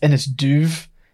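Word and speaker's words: Inis Dubh: And she is on an island that is pronounced very interestingly by some of the cast members, given how it's spelled, but it's Innisdu, and I'm pretty sure it's pronounced Innisduv, because they're taking the Gaelic pronunciation, but they're not.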